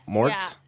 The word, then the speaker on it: Moretz: Moretz.